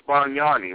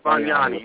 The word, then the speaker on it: Bargnani: It's Bargnani, right? – Bargnani, whatever. He's a scrub. – Yes, Bargnani, yes.